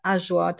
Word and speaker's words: ajwa: And there is one called the ajwa date. I don't know if I pronounce that particularly nicely, but it's spelled AJWA. And it's really small, and it's sort of round and very dark and kind of much thicker and chewier than a normal date.